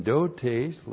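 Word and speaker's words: Dotes (δότης): When used with the noun dotes, and dotes looks like this. D-O-T, long E-S. Dotes.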